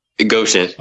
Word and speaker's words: Goshen: And I'm, I'm not even sure if I can pronounce it, but is it Goshen or Goshen? – Goshen.